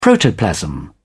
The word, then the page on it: protoplasm: British English